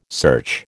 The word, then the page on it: search: American English